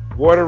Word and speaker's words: water: Water rhymes with order. Yeah, like order and water.